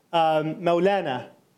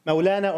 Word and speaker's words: Mawlana: Mevlevi because he himself was called Mawlana. Mawlana or Mevlana, I guess Mevlana would be the, I guess, Persian pronunciation.